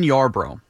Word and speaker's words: Yarbrough: Just make sure you say his name with two syllables, not three. – Ryan Yarbrough.